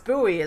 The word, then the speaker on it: buoy: Yeah, it's like, just like a boy in a sea, a boy and a buoy, a buoy, it's pronounced buoy is the word, a boy and a buoy in a sea.